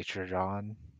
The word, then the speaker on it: Trajan: Trajan? How do you pronounce it? – I say Trajan.